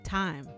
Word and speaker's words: thyme: It's thyme. – It's thyme. – It's thyme. – It's an H in there. It's thyme. – They say the H is silent. It's called thyme.